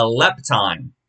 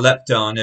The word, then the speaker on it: lepton: The Greek word for this mite is a lepton, or a lepton if you're trying to pronounce it accurately to the Greek.